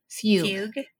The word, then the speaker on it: fugue: Fugue. – There you go. Yeah. – It's like I keep wanting to say it like huge.